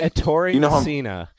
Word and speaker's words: Ettore Messina: Ettore Messina.